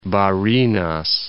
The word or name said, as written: Barinas